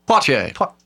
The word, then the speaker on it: Poitier: Sidney Poitier. – I know I'm not pronouncing it right. – No. Poitier. Yeah, you know, of the Jackal fame.